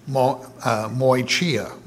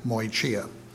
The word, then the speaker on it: moicheia: And that word is to be distinguished from the word for adultery in the Greek language, moichia, moichia, M-O-I-C-H-E-I-A, moichia.